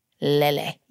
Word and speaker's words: Lele: Lele is how you say it, not Lele. I thought it was Lele, but it's not. It's Lele.